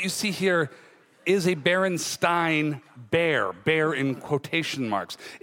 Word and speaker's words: Berenstain: I grew up with the Berenstain Bears, and it's pronounced Berenstain.